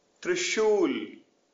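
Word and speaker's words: Trishul (त्रिशूल): Thr, like thr in through with a rolled R. Trishul, trident.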